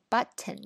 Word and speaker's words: Button: 好，这个 Button 也是我们之前学的那个音的这个 T hold 住的那个发发音方法，Button，Button。